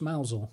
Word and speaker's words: Mousehole: He played famously for Mousehole. – Yeah. In Cornwall. – Yeah. – It's pronounced Mousel.